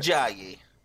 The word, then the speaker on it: Ajayi: NFL star Jay, I'm going to say it wrong. I know it. Jay. – How do I get it? – Ajayi. A-J-A-Y-I. – Tell Hunter who he plays for until now. – A-J- what? – A-J-A-Y-I.